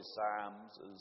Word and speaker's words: Psalms: And I'll work hard at that word Psalms. It's really Psalms is the correct pronunciation.